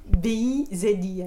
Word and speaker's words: bizi: BIZI. – Exactement, parce que basque, le Z se prononce comme un S en français.